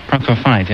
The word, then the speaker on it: Prokofiev: And he took the theme from this, from Sergei Prokofiev, I think. Prokofiev, yes.